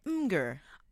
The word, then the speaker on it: Imgur: Celeste is in Colorado, but Shula took to Imgur. Am I saying that right? Imgur. – That's how I say it, but I also said like Mimi for a while.